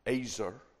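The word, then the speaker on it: ezer: Helpmeet is this, in Hebrew you'd pronounce it azer, and it really means a perfect opposite.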